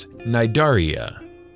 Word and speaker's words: Cnidaria: Hydra are members of a phylum of animals known as Cnidaria. That's C-N-I-D-A-R-I-A. The C is silent.